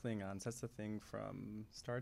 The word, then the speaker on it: Klingons: Okay, but this word is Kilgon. No, that's not correct. – Klingons. – Is that really how you pronounce yes.